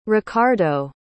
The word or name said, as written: racardo